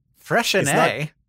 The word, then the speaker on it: Freixenet: Freixenet.